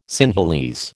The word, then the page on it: Sinhalese: American English